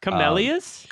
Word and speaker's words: camellias: One of the interesting facts about this film is that all of the, I can never pronounce it, is the chamomiles, the flowers were actually kind of like artificially made or... – Camellias? – Camellias, thank you.